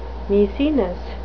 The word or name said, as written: maecenas